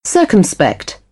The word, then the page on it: circumspect: British English; ɜː